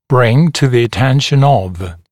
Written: bring to the attention of

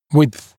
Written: width

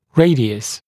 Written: radius

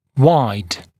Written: wide

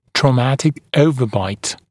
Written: traumatic overbite

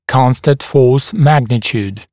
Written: constant force magnitude

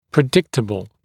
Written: predictable

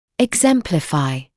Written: exemplify

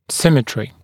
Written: symmetry

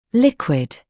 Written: liquid

